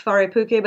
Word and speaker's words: Wharepuke: So it's it's pronounced Faripuke, but it's spelled W H. And anytime you see a W H, it's an F sound. Wharepuke is they have an art studio,